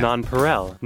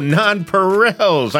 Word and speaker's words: nonpareils: Snow caps are non-perennials? How do you say that? – Non-perel. – Oh, non-perels.